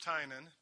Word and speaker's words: Tynan: I'm Tynan. That is pronounced Tynan, T-Y-N-A-N.